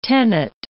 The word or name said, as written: tenet